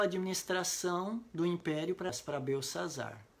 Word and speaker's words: Belsazar: É, Belsazar é a pronúncia em hebraico, mas a pronúncia babilônica era Bel-Sar-Uzur, que significa o deus Bel protegeu o rei.